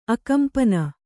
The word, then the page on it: ಆಕಂಪನ: ākampana